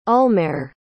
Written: ulmarr

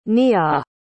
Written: nea

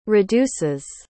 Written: reduces

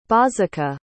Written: bozica

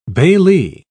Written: bailee

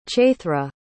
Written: chaithra

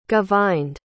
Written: govind

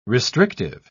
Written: restrictive

